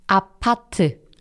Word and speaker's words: 아파트: 아파트. – It's not apartment. – It's 아파트. – 아파트. So one thing that you need to make a clear distinction out of is when somebody says 아파트, 네.